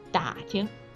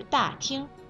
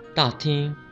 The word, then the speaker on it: 打听: 所以啊，你想说的是打听，而不是大厅。右边，不知道你现在听出区别了吗？ – 啊，原来是声调又念错了，那我再来试一下吧。打听，打听消息，这会怎么样，猪猪？ – 嗯，这回就对了。